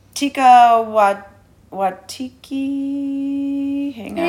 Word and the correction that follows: Taika Waititi: Oh, Taika Waititi.